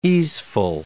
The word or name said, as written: easeful